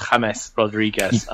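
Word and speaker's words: James Rodríguez: And Bryce, I'm glad you embraced the Spanish element there and went from calling him James to James Rodriguez. You got to drop off those Anglophile pronunciations and just get on. It's James Rodriguez.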